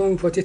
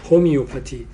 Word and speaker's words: هومیوپاتی: با اون میگفت هوموپاتی، البته هوموپاتی تلفظ فرانسه شما نشته، مثل اسلاید قدیمی موقعی که آقای دکتر گفتن که میتونیم یه صحبت کنیم، من نشستم از توی کامپیوتر مثل اسلاید قدیم و جدید درست کردم، الان دیگه تلفظ انگلیسی آمریکاییشون میگن هومیوپاتی، ولی این هوموپاتی خب اون چیز لفظ فرانسه است.